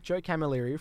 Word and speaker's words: Joe Camilleri: How do you say his name? – Joe Camilleri from the Black Sorrows. – Yeah. – Oh, no. – And I called him Joe Calamari live on air.